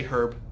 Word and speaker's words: herb: And the hand of the Lord shall, you know, the British say herb.